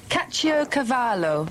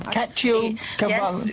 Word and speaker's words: Caciocavallo: Caciocavallo sounds Italian. – Yes, this is an Italian cheese, a little similar to provolone. – That Caciocavallo. – Yes, let me just say it again. Caciocavallo. – Caciocavallo.